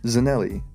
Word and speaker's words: Zanelli: And if you want to keep up with it, it's just called Zanelli Talks,